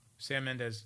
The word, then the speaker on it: Sam Mendes: Sam Mendes. I said that right? – Yeah. – I've got his name right? – Okay. No, you're right.